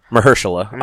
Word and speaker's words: Mahershala Ali: It's Mahershala Ali, or however you pronounce it. – Yeah, Mahershala Ali.